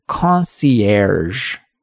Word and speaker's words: concierge: It's concierge, right? That ending sound is erge, which is a very rare sound in English, I feel.